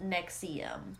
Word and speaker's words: NXIVM: rise and fall of Nexium, spelled in all capital letters, NXIVM, but it is pronounced Nexium.